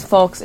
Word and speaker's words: folks: Cons in the Midwest are blessed that a lot of folks, how do you pronounce folks? Is it folks? Can I, okay, because I thought you weren't supposed to pronounce the L, but it sounds wrong without the L, like folks? – You could say folks.